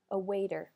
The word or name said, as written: a waiter